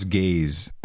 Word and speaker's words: Gaz: I think this he wants to pronounce gaze, I believe. – Gaze? – I think so, yeah. G-A-Z-E. But it was gaze. Gaze. I think it's gaze.